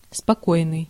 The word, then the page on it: quiet: /ˈkwaɪ.ət/; [ˈkwaɪ.ət]